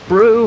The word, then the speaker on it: Brew: Unfortunately, Michael Knight altered my letter so that I pronounced your name Robert Barrick. It's in fact Robert Brew. All apologies from me.